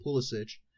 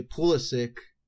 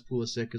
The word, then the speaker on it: Pulisic: And also, I just found out, because I have been calling him Pulisic this whole time, and I've been very adamant that his name is pronounced Pulisic, and stupid Americans say Pulisic, but he pronounces it as Pulisic as well, which, yeah.